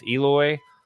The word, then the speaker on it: Eloy: All right, then the White Sox did a whole pronunciation where they actually recorded the players pronouncing their own name, and you just hit the button where it says play, and it's like, yeah, it's Eloy, which I thought.